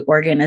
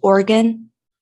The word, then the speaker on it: Oregon: So the correct way to say it is Oregon? – Yes. – Not Oregon? – Yes. I've heard Oregon is also not correct. – I was like, that's correct. That would really surprise me. So Oregon. – Oregon. – It's like two syllables.